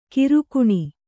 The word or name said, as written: ಕಿರುಕಣ್